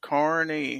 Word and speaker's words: Kearney: Gun website says Kearney. Defense Dad says yes, it's pronounced Kearney.